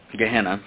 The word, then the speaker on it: Gehenna: Gehenna, and it's spelled G-E-H-E-N-N-A, Gehenna, and Gehenna literally does point to hellfire.